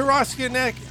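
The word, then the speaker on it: Ostanek: Walter Ostenek. Did I say that right? – Ostenek, yeah.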